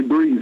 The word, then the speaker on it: Breathe: Alors, prochain morceau, c'est Brief, comment on prononce, Brief? – Alors, c'est Breathe, ouais.